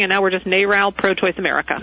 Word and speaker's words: NARAL: Is it pronounced Narrow or Gnarl or N-A-R-A? – NARAL Pro-Choice America.